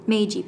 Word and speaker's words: Meiji: Meiji period.